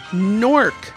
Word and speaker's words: Newark: It's a New Jersey thing to pronounce Newark as Nork. N-O-R-K.